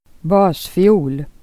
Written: basfiol

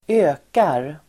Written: ökar

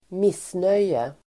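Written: missnöje

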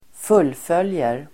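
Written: fullföljer

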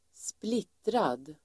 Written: splittrad

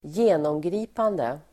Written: genomgripande

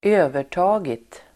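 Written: övertagit

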